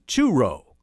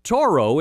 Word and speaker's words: Toro: tractor company, they'd often mispronounce the name Turo, calling it Toro instead.